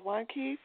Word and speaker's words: Wanky: I'm Sherry, and today's guest is Paul Wanky from Dead Original. I hope I pronounced that right, Paul. – Yeah, it's just Wanky, like I want a key.